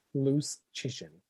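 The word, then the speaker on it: Dom Luszczyszyn: you had a ninety nine point nine seven chance of winning that game, according to Dom Lecision of the Athletic and you lost. Loose Chishin. What? Loose Chishin. – Sorry. – Loose Chishin. Loose Chishin. It's it rhymes with loose chicken.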